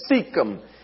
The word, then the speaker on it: Shechem: It's pronounced Shechem.